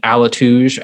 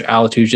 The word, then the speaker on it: Alituj: You can find me on Twitter at Lord Alituge. Alituge is spelled A-L-I-T-U-J.